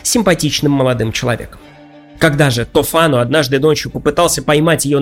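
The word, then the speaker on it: Tofano: В ней повествуется о богаче и ревнивце Тофано. Итальянцы произносят это имя как Тофано, но вы уж простите, я сохраню то произношение, к которому приучил меня Ерорд Масс, иначе говоря, третий участок, где мы обсуждали когда-то Декамерон с дядей Араиком.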